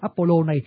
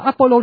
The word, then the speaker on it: Apollo: Người Việt Nam mình có lẽ không bao giờ nghe tên thần Apollo này, trừ ra nghe trong cái hỏa tiễn. Apollo này tiếng Pháp đọc là Apollon, còn ở trong tiếng Anh nó đọc là Apollo.